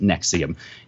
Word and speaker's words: NXIVM: Federal agents arrested and charged four more people yesterday for their roles in the alleged sex cult Nexium, N-X-I-V-M, pronounced Nexium.